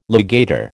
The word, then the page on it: legator: American English